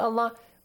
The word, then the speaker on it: Allah: The rule simply says, if there's a kasra before the word Allah, pronounce it with an A sound. If there's a fatha or a dhamma, pronounce it with an aw sound.